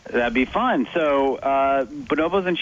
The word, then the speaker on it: bonobos: you also studied bonobos. Is that how you say it? Bonobos? – You got it. Absolutely.